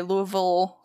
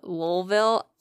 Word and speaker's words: Louisville: I love how people in the South pronounce it Louisville. – Louisville. – It's Louisville.